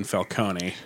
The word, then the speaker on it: Falcone: Well, the Nolan trilogy kept calling him Falcone, and it bothered me because in any other property, it's always been Falcone. – Well, and it's Italian, and you would pronounce the E.